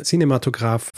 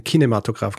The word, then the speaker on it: Kinematograph: Du nennst ihn jetzt Cinematograph, vorher hast du ihn Kinematograph genannt. – Naja, das Kinematograph ist einfach nur die deutsche Variante und Cinematograph wäre, glaube ich, die französische Aussprache.